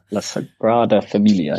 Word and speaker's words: La Sagrada Familia: La Sagrada Familia.